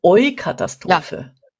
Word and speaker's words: Eukatastrophe: Doch, aber weil das Wort hatte ich zwar schon irgendwo mal gehört, aber auch erst vor kurzem, ich weiß gar nicht, wie spricht man es denn aus? U-Catastrophe. Die Eukatastrophe. – Ja, okay, ja.